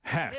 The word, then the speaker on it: Hoeft: It's Heft. – Heft. – It's Heft.